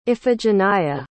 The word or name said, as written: iphigenia